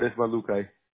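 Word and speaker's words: Ismajlukaj: No, it would be Emiliard Ismailukai. – Okay. Ismailukai? Oh, okay. – Yep. – You don't pronounce the J's then, right? – No.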